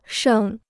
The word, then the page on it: 盛: chéng